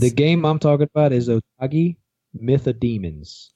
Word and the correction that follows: Otogi: O-T-O-G-I. – Oh, Otogi. – Oh, I'm saying it wrong.